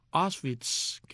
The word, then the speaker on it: Auschwitz: Este no es el caso, me tengo aquí las notas, de manera que Auschwitz, que es la manera de pronunciarlo en alemán o en polaco, Auschwitz en español o en inglés, se escribe A-U-S-C-H-W-I-T-Z, campo de concentración.